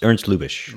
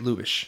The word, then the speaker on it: Lubitsch: Is that how you pronounce his last name? – Yeah, Ernst Lubitsch. – Lubitsch, thank you.